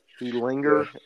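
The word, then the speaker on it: Ehlinger: He Linger. Yeah. Yeah, I think is how you say his name.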